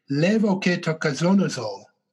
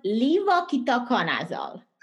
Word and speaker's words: levoketoconazole: levoketoconazole. Did I say that right? – It's called levoketoconazole. – Levoketoconazole. Okay, thank you.